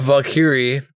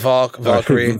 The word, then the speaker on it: Valkyrie: And then this week we've got Valkyrie. Why did I say that wrong? We got Valkyrie.